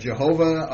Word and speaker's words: Jehovah: Okay, some would pronounce it Jehovah. Others would pronounce it Yahweh, depending on your whether it was German or Aramaic or what have you.